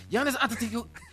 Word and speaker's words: Giannis Antetokounmpo: Giannis Antetokounmpo. The Greek Freak is going to stay in Milwaukee. That's all we need. Giannis Antetokounmpo.